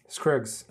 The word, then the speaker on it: Scruggs: Você tem o Buster Scruggs, Scruggs, perdão. É, como é que fala? Scruggs.